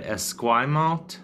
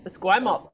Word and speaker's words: Esquimalt: We stayed in how do you say it, Esquimalt? – Yeah, you nailed it. Yeah, Esquimalt, perfect.